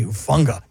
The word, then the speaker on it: Hufunga: In fact, Stafford actually had a pretty good angle on, how do you say the kid's name? Lufunga? – Hufunga. – God, I love that guy's life. It just sounds fun to say, Hufunga, you know?